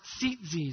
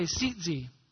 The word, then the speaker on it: tzitzit: So it's actually pronounced tzitzi. So everybody say tzitzi. Now, if you're taking notes, which I hope you are, it's actually spelled T-Z-I-T-Z-I-T. So it looks like T-Z-Z, right? So tzitzi would hang down.